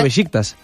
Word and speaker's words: Beşiktaş: Beşiktaş.